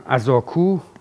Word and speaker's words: ازاکو: ازاکو تلفظ بومی از کلمه آزادکو هست، معلومه دیگه اسم یک قله است.